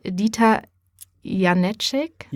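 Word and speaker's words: Janecek: Und beim Zukunftsforum, jetzt musst du mir nochmal helfen, ist zu Gast Dieter Janicek, genau. Die Betonung liegt auf der ersten Silbe.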